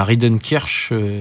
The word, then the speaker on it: Rinderknecht: On a Rinderknecht.